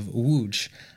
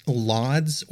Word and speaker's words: Łódź: Before we go any further, I do want to mention that when I'm talking about this city of Łódź, I'm actually referring to what you may know as LODS or LODS or LODGE or however else you might pronounce the letters L-O-D-Z in English. That's not how it's pronounced in Poland, so I'm going to go with the Polish pronunciation, which hopefully I'm pretty close with.